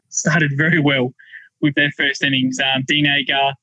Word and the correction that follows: Elgar: Elgar, sorry.